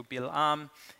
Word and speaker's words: Bilam: B-A-A, Ba'alam, I think it's written different ways, but, you know, but basically it's pronounced in Hebrew, Bilam.